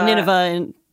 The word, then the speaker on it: Nineveh: Nineveh? Is that how you pronounce it? – Uh-huh. – He was of leading Nineveh. – Nineveh in American English, yeah.